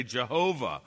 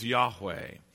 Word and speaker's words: Yahweh: I think the scholars today make a very good case that a more accurate rendering of a name, which was given to be said, is Yahweh, the great name of God, I am.